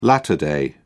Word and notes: latter day: British English

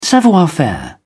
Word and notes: savoir-faire: British English

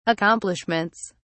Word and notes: accomplishments: British English

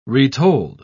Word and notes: retold: British English

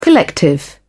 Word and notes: collective: British English

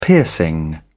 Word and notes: piercing: British English

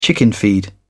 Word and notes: chickenfeed: British English

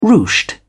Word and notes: ruched: British English

